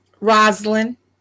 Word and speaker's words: Rosalind: Rosalind.